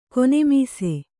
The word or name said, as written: ಕೊನೆಮೀಸೆ